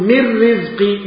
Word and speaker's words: من رزق: كلوا من رزق ربكم، من رزقي، من رزقي، كأنك نطقت ميم وبعدها راء على طول، ولا وجود للايه؟ للنون، لا وجود للنون، لأنها أدغمت في الراء، أدغمت النون الساكنة في الراء.